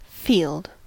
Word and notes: field: American English; [fild]; /fiːld/

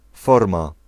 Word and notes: vorm: /vɔrm/

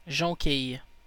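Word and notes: jonquille: Canada; IPA: /ʒɔ̃.kij/

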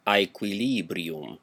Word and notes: aequilībrium: aɪ.kʷiˈliː.bri.um; Roman Classical

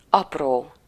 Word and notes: small: /smɔl/; /smɔːl/; /smɑl/

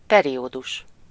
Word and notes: period: American English

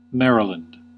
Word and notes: Maryland: American English; /ˈmɛəɹɪlənd/; /ˈmɛɹələn/

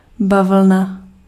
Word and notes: cotton: American English; IPA : [ˈkɒt.ən]

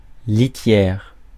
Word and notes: litière: /li.tjɛʁ/; France

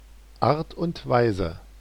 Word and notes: guise: /ˈɡaɪz/

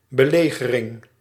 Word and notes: siege: [sidʒ]; /siːdʒ/

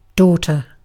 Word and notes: tyttö: IPA: /ˈtytːø/

